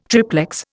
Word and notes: triplex: Indian

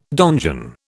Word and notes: donjon: British English

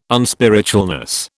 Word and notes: unspiritualness: British English

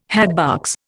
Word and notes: hatbox: Indian

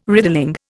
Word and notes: riddling: Indian

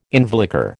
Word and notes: involucre: American English

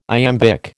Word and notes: iambic: American English

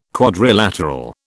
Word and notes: quadrilateral: British English

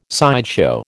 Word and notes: sideshow: American English

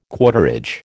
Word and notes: quarterage: American English

